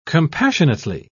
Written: compassionately

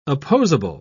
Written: opposable

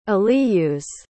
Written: aliyus

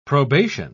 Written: probation

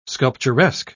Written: sculpturesque

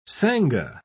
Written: sanga

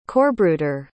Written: corpsbruder

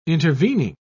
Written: intervening

